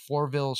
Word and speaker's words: Fourville: And then another one that I was really interested in was the Fourville story. It's Fourville. It's a story driven character interaction. – How do you spell that? – Fourville? Fourville. It's about Fourville. – Like the number Fourville. Yeah.